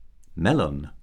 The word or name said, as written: melon